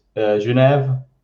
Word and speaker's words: Genève: Depends on what language you're trying to pronounce it in. So in English, it's Geneva. French, it's Genève. Mm. And we German, because I'm in the German-speaking part in near Zurich. – Yeah. – And we just say Genf.